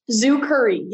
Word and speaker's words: Zucuri: Zucuri, yes. So things Zucuri. Like zoo and then curry, like the spice.